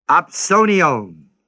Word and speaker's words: opsonion: The word wages is the Greek word opsonion, O-P-S, long O-N-I-O-N.